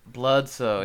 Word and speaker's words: Bledsoe: Bledsoe? – Yeah, only because Bledsoe said so, it rhymes. – Bledsoe, yeah.